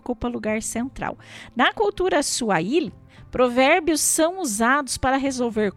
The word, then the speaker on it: Swahili: Na verdade, a pronúncia é Swahili, né? Eles falam diferente da gente.